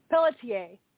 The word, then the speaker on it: Pelletier: Adam Minarovich, Ed Pelletier, okay, Joan went and got me the damn pronunciation, so let me go find it. Pelletier, okay, since she went to the bother to get that for me.